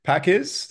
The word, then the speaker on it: Pakiz: which is Keith Pakiz.